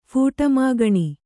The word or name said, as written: ಫೂಟಮಾಗಣಿ